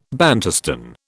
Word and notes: Bantustan: British English

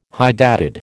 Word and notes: hydatid: American English

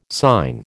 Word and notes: sign: American English

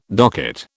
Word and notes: docket: British English

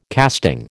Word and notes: casting: American English

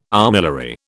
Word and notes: armillary: British English

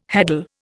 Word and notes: heddle: Indian

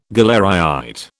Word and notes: galleryite: British English

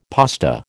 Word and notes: pasta: American English

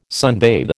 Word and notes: sunbathe: American English

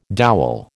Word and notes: dowel: American English

